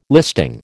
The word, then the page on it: listing: American English